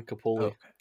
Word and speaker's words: Capouellez: My name's Aaron Capouellez.